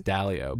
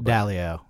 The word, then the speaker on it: Dalio: You wrote a profile of Ray Dalio. Mm-hmm. Am I pronouncing that correctly? – I think it's Dalio. – Dalio. Yeah, it's a little bit more emphatic.